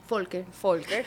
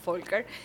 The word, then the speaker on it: Volker: el ciudadano alemán Karl Volker, ¿lo pronuncié bien? – Volker. Recordemos, la B pequeña es F. – Ok, Volker.